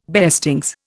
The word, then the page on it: beestings: Indian